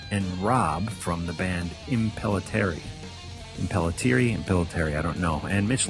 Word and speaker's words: Impellitteri: Yeah, I think it's Impellitteri. – I think so. – Yeah. I think that's it.